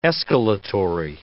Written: escalatory

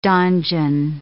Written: donjon

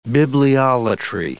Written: bibliolatry

